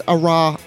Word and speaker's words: Oroho: I don't know how to say his name. I think it's Oroho.